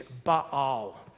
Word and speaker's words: Baal: You know the god Baal, two A's, Baal.